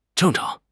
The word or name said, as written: zheng4 chang2